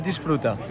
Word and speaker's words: Disfruta: How do you say it? – Disfruta. – Disfruta, okay.